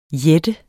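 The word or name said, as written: JÆTTERNES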